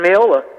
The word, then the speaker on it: Mayola: And Mayola, it rhymes with payola.